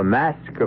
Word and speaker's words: masque: The Mask of the Red Death. The word mask, you should know, is spelled M-A-S-Q-U-E and means masquerade.